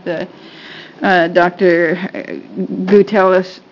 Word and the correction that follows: Gutelius: No, I said it wrong. – Sorry. Sorry. – I want to get it right. Gutelius, he explained it very well.